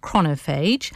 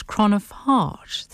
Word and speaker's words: chronophage: So I asked Dr. John, first of all, how you pronounce chronophage. And as I said earlier, he said, it depends on where you come from, either north or south. So me, coming from south, I would pronounce it chronophage. Dr. John C. Taylor pronounces chronophage. And there is also another pronunciation, which is chronophage.